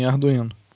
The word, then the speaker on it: Arduino: Eu acredito que é assim que se pronuncia em português. Arduino, é isso mesmo? – Sim, sim, Arduino.